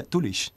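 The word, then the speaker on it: Doolish: Claro, por ejemplo, en relación a lo que dices, la ciudad principal de Man se escribe Douglas, como Kirk Douglas, uh-huh. Pero vale, agárrate a cómo se pronuncia Tulish.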